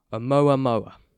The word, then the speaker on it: Oumuamua: His name is pronounced Oumuamua.